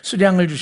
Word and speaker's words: 숫양: 제가 발음이 숫양이라고 자꾸 그러고 있죠. 예전에 사용하던 개역성경은 수양이라고 되어 있어요. 근데 우리 개정성경, 새로 된 성경은 숫양이라고 되어 있어요. 표준어 사전을 찾아보니까, 거기를 통해서 맞춤법을 찾아보니까 숫양이 맞네요.